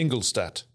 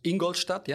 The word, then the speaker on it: Ingolstadt: Ingolstadt. – Ingolstadt, yeah, and Neckarsulm. We have Ingolstadt. It's in the Bavarian region, Ingolstadt, and here in the Sweden region we have Neckarsulm, and still our customer.